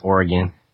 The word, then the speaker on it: Oregon: So you're in Oregon, and am I saying that right? If I out here in New Hampshire, we say Oregon. Is that... – Yeah, it's Oregon. – Yeah, someone said it was Oregon. I said, no, it's not, you're missing syllables.